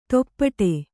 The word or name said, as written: ತೊಪ್ಪಡೆ